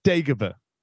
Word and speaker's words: Dagobah: It's pronounced Dagobah.